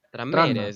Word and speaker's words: Tranmere: cómo, se pronuncia, Tranmere. – Tranmere, sí. – No, Transmere queda muy bruto.